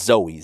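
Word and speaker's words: Zoe (ζωή): If you look this up, it's pronounced Zoe, Z-O-E.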